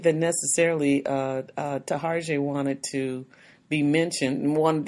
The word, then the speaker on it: Taraji: He talks about his relationship with Chloe, with always saying her name wrong. Tarjay. – Yeah. – Taraji, boy, we just hints and we just cut up the girl's name.